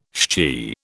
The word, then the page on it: shchi: British English